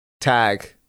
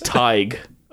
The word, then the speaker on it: Tadhg: T-A-D-H-G. How would you pronounce that? – T-A-D-H-G. – H-G. Yeah. – Tag. – Oh. You're it. – Tug. – Very close. Yeah, I'll give it to you. It's Tig.